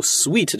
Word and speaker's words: suite: 我不知道你们自己在读的时候会怎么读中间那个 S-U-I-T-E 这个单词，或者你在听到我读 sweet 的时候能不能反映出这个单词，因为这个词很多同学会直接把它读成 S-U-I-T 的那个词叫 suit。